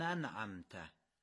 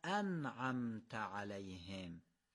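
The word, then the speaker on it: أنعمت: الف انعمته همزه قطعه، یعنی باید خوب بیان بشه، حذف نمیشه. مثل همزه وصل نیست که نخونیمش، باید قشنگ تیزیشو بگیم و تلفظ کنیم. یعنی بگیم صراط الذین انعمت علیهم.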